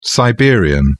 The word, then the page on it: siberian: British English